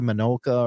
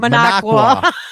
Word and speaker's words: Minocqua: Minocqua.